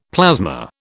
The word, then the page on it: plasma: British English